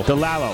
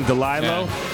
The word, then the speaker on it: Delalo: It's a very different experience from, is it Delilah or Delalo? – Delalo. – Delalo. – Yeah, I mispronounced it. I used to like saying Delilo, but it's Delalo.